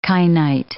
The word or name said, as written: kainite